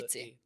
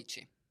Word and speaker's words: picci: P-I-C-C-I. – P-I-C-C-I. – Pici. Jako napsáno pici, ale vyslovené pokud... piči. Mm-hmm. Protože dvojité C, italsky to tvrdý Č.